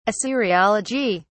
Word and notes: assyriology: British English

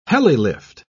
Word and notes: helilift: British English

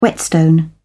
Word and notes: whetstone: British English

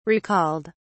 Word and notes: recalled: British English

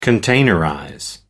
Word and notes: containerize: American English

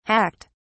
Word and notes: hacked: British English

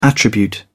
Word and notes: attribute: British English

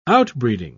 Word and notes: outbreeding: British English

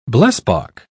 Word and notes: blesbok: British English

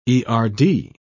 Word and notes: erd: British English

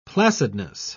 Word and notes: placidness: British English

